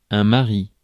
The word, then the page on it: mari: France; IPA: [ma.ʁi]; French